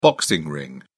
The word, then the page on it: boxing ring: British English